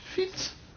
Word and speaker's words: Fitz: C'est le mot français, l'ancien mot français avec la prononciation de l'ancien français, Fitz.